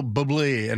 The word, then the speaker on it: Bublé: Michael Bublé, and it's B-U-B-L-E, and the little French, the thingy, the switch thing with the E. – Oh, yeah, that thing. – The emphasis. Put the emphasis on the right syllable, but it's a great song.